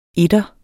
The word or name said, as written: etter